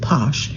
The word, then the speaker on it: Pox: One of the first commercial bottlings of Pox, I'm seeing it written P-O-X, but they pronounce it Posh, available in the United States.